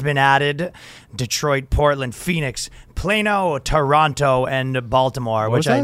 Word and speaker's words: Plano: Oh, well, I was just shocked you said Plano.